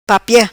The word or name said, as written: Papia